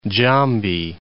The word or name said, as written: Jambi